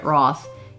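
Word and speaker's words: Routh: It's Brandon Ralph, I think is how you say it, Roth.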